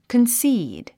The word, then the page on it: concede: kənsíːd; コンスィード